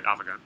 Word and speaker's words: Avegant: Did I say that right? – Yeah, that's right, Avegant.